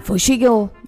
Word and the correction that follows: Fushigi no Oshiro Daiboken: Nej, vi måste uttala det rätt. Också. Fushigi no Oshiro Daiboken.